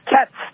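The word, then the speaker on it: Kaatz: Couldn't tell because of that double A whether it was Katz or Katz, but it is Katz, like the feline.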